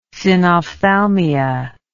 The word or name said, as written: synophthalmia